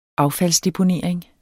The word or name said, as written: affaldsdeponering